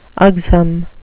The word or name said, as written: ugsome